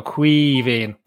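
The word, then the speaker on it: Caoimhín: Think the best way to imagine how to pronounce it, Neil, is imagine you're half pissed, right, and just go Quivín, and there you go. That's simple. – It's like an E, but it's an I, isn't it?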